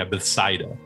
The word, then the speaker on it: Bethsaida: Then you have Capernaum, and then Bethesda, right? – Yeah, Bethsaida. – Oh, is that how you say it? Bethsaida? – Yeah. Bethsaida or Bethsaida.